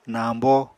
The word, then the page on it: nañbo: Apatani